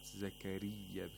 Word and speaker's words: زكريا: زكريا, the mouth is empty and it's spread, the lips are spread. Zakariya, because you're pronouncing the ya, the ya ul-mad.